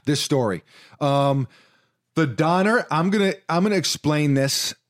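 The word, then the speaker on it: Döner: But I was just going to say that we got a thing called a donner, which. – We did. A donner, a donor. We don't know. – The guy was pronouncing it Duner. He was a German guy.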